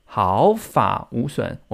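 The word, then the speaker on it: 毫髮無損: 刚刚听的发音是中国的发音，台湾的发音是毫发无损。我们那个发念三声，毫发无损。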